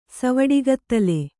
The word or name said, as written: ಸವಡಿಗತ್ತಲೆ